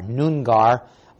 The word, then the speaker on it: Noongar: beginning at Brookton in Western Australia among the N-O-O-N-G-A-R, Noongar, or as they pronounce it, Noongar, tribes.